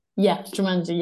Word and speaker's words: Jumanji: It was a bit inspired by Jumanji. Did I pronounce it right? Jumanji, the film with Jumanji? – Jumanji, yeah.